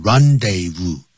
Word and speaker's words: rendezvous: The Moulin Rouge was the rendezvous, 这念成 rendezvous, du high life.